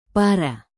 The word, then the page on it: para: indonesian